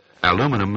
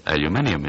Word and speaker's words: aluminium: But, of course, even though they might be two different words, I'm not conscious of the fact that aluminum, as we refer to it, is what the English refer to as aluminium. Is that right? – That's right. And it's spelt the same and pronounced differently.